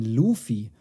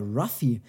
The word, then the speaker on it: Ruffy: Luffy. Und jetzt ist die Frage, was ist denn hier richtig? Und richtig ist tatsächlich die Aussprache Ruffy. Der heißt nämlich auch im Japanischen Ruffy.